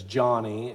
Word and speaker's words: Joni: And interesting, it's spelled J-O-N-I. It looks like maybe like it's Joni, but it's Johnny.